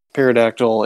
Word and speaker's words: pterodactyl: what you assume is a pterodactyl and or a pterodactyl. Jeez, I said pterodactyl. I pronounced the P.